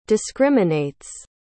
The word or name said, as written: discriminates